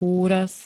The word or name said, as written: úraz